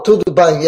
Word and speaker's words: tudo bem: I said "yo todo bem." I don't know if I said it right. Is that Portuguese? – Oh, tudo bem. Yes.